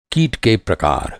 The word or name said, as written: प्रकार